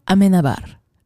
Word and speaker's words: Amenábar: It came out in 2001, written and directed by Alejandro Amenábar. Did I say that right? – Yep.